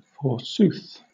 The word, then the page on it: forsooth: British English